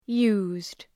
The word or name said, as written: used